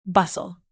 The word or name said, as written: bustle